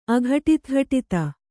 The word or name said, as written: ಅಘಟಿತಘಟಿತ